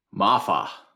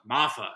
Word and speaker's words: Mafah: Mafah. – Mafah.